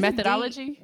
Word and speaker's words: methodology: I do, but okay, let me tell you my mythology. Mythology? Did I say it correctly? I feel like there's methodology. – There it goes. I'm missing a syllable.